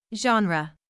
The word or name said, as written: genre